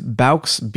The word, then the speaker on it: bauxite: From what I understand, I heard it pronounced as bauxite.